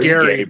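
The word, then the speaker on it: Gerry: And it's hard because it, like, some of the tragedies it portrays in there, like, with is his name Jerry or Gary? Jerry? – Gary Bertie. – Spelled like it's Jerry.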